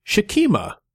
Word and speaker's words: Sekhmet: And it standing in their place was Sekhmet, or I learned that the ancient Egyptian pronunciation was more likely Shekhmet.